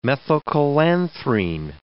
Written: methylcholanthrene

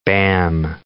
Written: bam

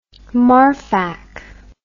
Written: Marfak

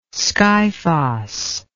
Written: skyphos

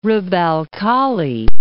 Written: Rub' al-Khali

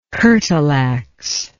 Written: curtal ax

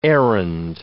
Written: errand

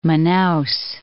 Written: Manaus

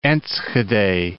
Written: Enschede

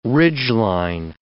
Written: ridgeline

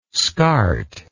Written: scart

